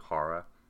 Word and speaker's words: Jara: I'm no pronunciation snob, but I think it's probably Jara.